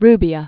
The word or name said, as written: Carlo Rubbia